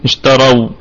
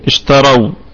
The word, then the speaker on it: اشتروا: اشتروا، فنقول بالسكون وجه بالسكون اشتروا، لكن يعني هذا الألف الذي ترونه بعد الواو هذا يسمى ألف فرقة، ألف فرقة يعني لا ينطق لا يلفظ، لا لا يجري بها، لا يجري بها التحريك.